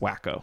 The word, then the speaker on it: Waco: we don't want another Waco on our hands. And Aaron says it's pronounced Wacko.